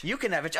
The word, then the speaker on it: Yukinevich: Jinakevich? – Not even close. Yukinevich. – Yukinevich. Oh, okay. So I just I had the first consonant off.